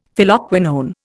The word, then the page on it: Phylloquinone: Indian